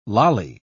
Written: lolly

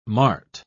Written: mart

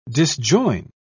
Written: disjoin

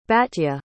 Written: batya

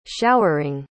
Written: showering

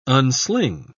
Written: unsling

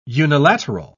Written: unilateral

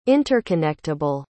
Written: interconnectable

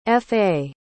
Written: fa

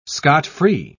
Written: scot-free

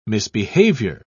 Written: misbehavior

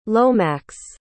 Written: lomax

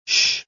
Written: sh

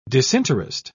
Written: disinterest

